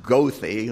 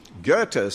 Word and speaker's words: Goethe: Goethe, the great German Enlightenment scholar, not pronounced Goethe like some do who don't know that particular language, Goethe said, "Only God knows who I really am, and may he preserve me from finding out."